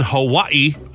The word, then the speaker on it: Hawaii: A Humane Society volunteer in Hawaii. I say it like the natives.